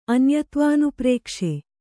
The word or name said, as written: ಅನ್ಯತ್ವಾನುಪ್ರೇಕ್ಷೆ